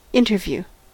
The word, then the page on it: interview: American English